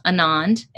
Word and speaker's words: Anand: On Twitter and Instagram, you can find me Nisha M, as in Marie, which is my middle name, Nisha M Anand, A-N-A-N-D.